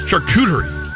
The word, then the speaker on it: charcuterie: Pepperoni pizza is technically charcuterie.